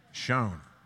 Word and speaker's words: shone: How do you pronounce that? Shone. Thank you, my English friends over here.